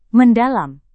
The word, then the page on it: mendalam: Indonesian